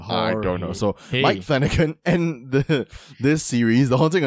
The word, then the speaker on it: Flanagan: or as I like to say it, Mike Flanahan. It's really Mike Flanagan, though.